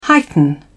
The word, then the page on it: heighten: British English